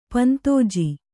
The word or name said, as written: ಪಂತೋಜಿ